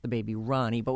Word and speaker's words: Rani: R-A-N-I. Pronounced like Ronnie.